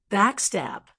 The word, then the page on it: backstab: American English